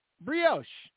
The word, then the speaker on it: brioche: Brioche.